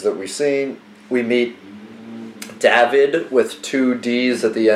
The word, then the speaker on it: David: You know that in Hebrew, every David that you're going to meet, it's David.